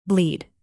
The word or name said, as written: bleed